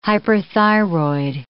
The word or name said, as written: hyperthyroid